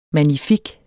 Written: magnifik

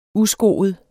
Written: uskoet

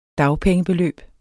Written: dagpengebeløb

